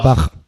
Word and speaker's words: Bach: Bach.